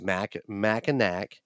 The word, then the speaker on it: Mackinac: I did a pronunciation on an app. I spelled it out, and you heard it in that episode. And it's Mackinac. – Well, yeah, you look at how it's spelled, there is no really disputing it. Because like I said, there is no language that I know of that pronounces the NAC as gnaw.